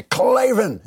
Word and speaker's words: Klavan: How did you hear about us box so they know we sent you and you want to say it like they want to say Klavan and then you want to spell it K L A V A N.